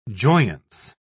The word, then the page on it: joyance: British English